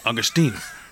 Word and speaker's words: Augustyn: Miss Katie Augustina? Is that how you say it? – Augustyn.